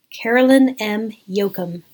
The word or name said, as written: Caroline M. Yoachim